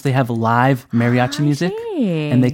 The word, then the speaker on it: mariachi: 특히 멕시코의 밴드로 mariachi 이렇게 발음을 해야지 정확하지 않을까 싶어요.